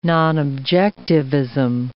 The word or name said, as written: nonobjectivism